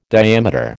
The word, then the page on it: diameter: American English